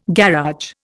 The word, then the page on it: garage: Indian